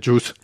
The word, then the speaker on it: JUICE: Y, ya que estamos hablando de pronunciación, no se pronuncia juice, se pronuncia juice en, inglés. – es como, como chus, ¿vale? Como, como chus lampreave, ¿vale?